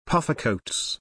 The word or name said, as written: puffer coats